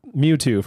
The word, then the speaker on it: Mewtwo: Mewtwo from Pokemon. – Yeah, you put the emphasis on the two.